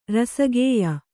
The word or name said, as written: ರಸಗೆಯ್